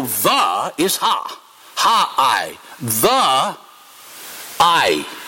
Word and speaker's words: Ai: Last week, our brother said he wasn't sure exactly how the word Ai is to be pronounced. Well, it's to be pronounced Ai.